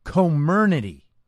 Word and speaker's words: Comirnaty: It's called Comirnaty, right? Apparently, that's the right way to pronounce the new brand name of the BioNTech Pfizer-like vaccine, right?